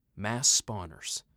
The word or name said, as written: Mass Spawners